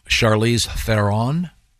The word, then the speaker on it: Charlize Theron: Now, born in 1975, and I don't know how to pronounce this name. I've heard it so many different ways. Charlize Theron? – Yeah. – Is that right? – I think you did it right.